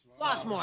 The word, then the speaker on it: Swarthmore: Swarthmore. – Swarthmore. Yeah. All right. Yeah. There's an R in there, you know. – I know. – But they skip over that.